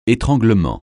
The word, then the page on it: étranglement: français